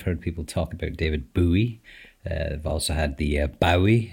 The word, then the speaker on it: Bowie: It's the new episode of the podcast where it's pronounced Bowie. That's correct, isn't it? There's a few different attempts to pronounce this person's name, but I've heard him say it, and it's Bowie.